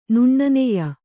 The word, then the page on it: ನುಣ್ಣನೆಯ: nuṇṇaneya